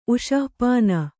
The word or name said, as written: ಉಷಃಪಾನ